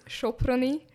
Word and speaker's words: Soproni: Yes, can you please call it? It's pronounced as Soproni.